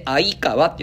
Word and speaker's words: 鮎川: 鮎川って書いて鮎川って読むんですが、